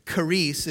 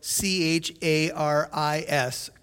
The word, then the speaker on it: charis: It's charis, it's spelled C H A R I S, charis.